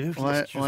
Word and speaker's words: Clicquot: Veuve Clicquot. Ah, c'est Veuve Clico.